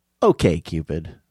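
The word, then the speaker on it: OkCupid: It should be pronounced okay, Cupid.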